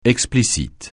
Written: explicite

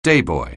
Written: dayboy

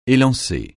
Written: élancer

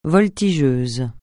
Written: voltigeuse

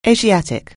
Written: Asiatic

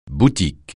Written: boutique

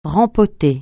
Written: rempoter

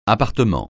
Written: appartement

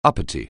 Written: uppity

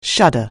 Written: shudder

